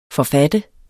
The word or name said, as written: forfatter